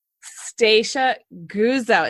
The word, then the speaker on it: Stacia Guzzo: Stacia Guzzo.